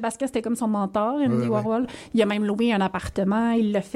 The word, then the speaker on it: Andy Warhol: Et à un moment donné, il y a quelqu'un qui lui a présenté Andy Warhol. – Oui. – Je le prononce pas bien. – Mais... Andy Warhol. – Oui, merci.